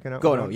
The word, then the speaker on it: Gano: Is it Gono? That's how you say it? – Gono, yeah. – Gono.